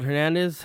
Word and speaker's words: Hernandez: My name is Gerald Hernandez.